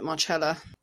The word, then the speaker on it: Marcella: You apparently pronounce it Marcella.